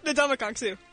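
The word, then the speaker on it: Ndamukong Suh: How do you say his name? – Why was... – Not... – You did it right. – Ndamukong. – Ndamukong Sue. – Right. That's what I said.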